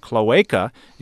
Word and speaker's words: cloaca: The correct pronunciation of the word cloaca is with a long A sound in the middle, not a short one.